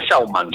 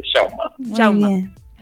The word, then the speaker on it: Schauman: Schauman. ¿Cómo es? – Santiago Schauman. – Pero lo pronuncié bien, bueno. Muy bien. – Difícil de pronunciar el apellido, pero sí, Schauman.